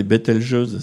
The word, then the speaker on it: Betelgeuse: Oui, Betelgeuse, si je peux me permettre une parenthèse pour savoir comment le prononcer, il y a eu un film il y a des années, Betelgeuse, qui vient de Betelgeuse.